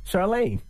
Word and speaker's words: Chirlane: Charlaine.